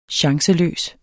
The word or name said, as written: chanceløs